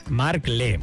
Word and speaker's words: Markle: Markle, muy bien, ahí está. Markle. Bien. Pronunciación británica.